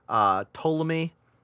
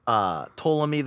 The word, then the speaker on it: Ptolemy: Ptolemy, the P is silent. You might have to say Ptolemy again later. – I understand that the P is silent. – Ptolemy, the Greek astronomer, who said that sometimes the gods peer down at us, and when that happens, stars will slip through.